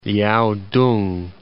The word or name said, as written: Liaotung